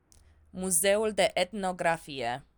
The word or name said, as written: muzeul de etnografie